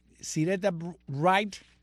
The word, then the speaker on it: Syreeta Wright: ¿Cómo se pronuncia esto? Syreeta Wright. ¿Syreeta? Syreeta.